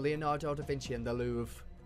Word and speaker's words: Louvre: Yeah, it's a Louvre. Louvre. – The Louvre. – Yeah, it's spelled like Paris. Louvre. – Yes. – But it's Louvre.